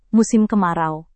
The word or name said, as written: musim kemarau